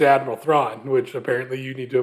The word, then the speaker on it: Thrawn: And in the trailer, little teaser they showed, you see the back of Admiral Thrawn.